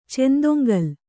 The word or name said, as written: ಚೆಂದೊಂಗಲ್